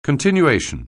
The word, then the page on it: continuation: anglais